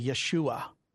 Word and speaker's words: Yeshua: You wouldn't pronounce the J in Hebrew, so it would be Yeshua. Joshua.